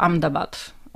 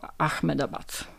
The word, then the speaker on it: Ahmedabad: Hindusi wymawiają w zasadzie tak ludzie z Gujaratu to jako Ahmedabad. My Polacy czytamy to jako Ahmedabad. No ale będziemy się raczej trzymać tej nazwy Ahmedabad.